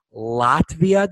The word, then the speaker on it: Latvia: She is from Latvia. Did I pronounce that correctly? – Yes, you did.